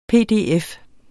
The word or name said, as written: PDF